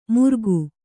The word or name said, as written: ಮುರ್ಗು